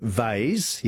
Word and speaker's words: vase: And Robert and Mitch and David in Adelaide, we say vase, yes, V-A-S-E, rhymes with haze, but we also say Lego for Lego, L-E-G-O.